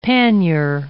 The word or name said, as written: pannier